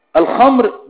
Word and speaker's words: الخمر: جبل الخمر، الخمر بخاء معجمة وميم مفتوحة، الخمرة يعني تنطق هكذا، الخمرة.